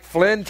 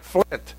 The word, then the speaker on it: Flint: If you say in like Flynn, technically you're wrong because it's in like Flint. There's a T there.